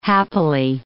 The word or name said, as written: happily